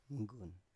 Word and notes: mgun: Biak

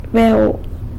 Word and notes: beu: sanlucasquiavini

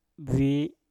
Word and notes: bwi: Mpur

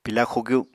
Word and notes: pila xugyu: Apatani

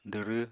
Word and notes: dwrw: apatani

